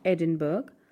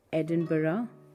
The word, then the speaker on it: Edinburgh: Another city that is pronounced with more syllables than it looks like it would be Edinburgh. It is not Edinburgh, but Edinburgh.